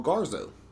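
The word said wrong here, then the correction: Garzón-Montano: Garzon Montano.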